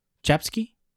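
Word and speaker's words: Czapsky: How do you pronounce a C and a Z? Is it Chapsky? – Yes.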